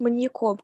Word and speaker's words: маньяком: У меня сейчас с этим человеком в костюме появилась ассоциация с маньяком, который выглядит абсолютно по-обычному, но маньяком. При этом... – Ударение на «я». Маньяком. Окей.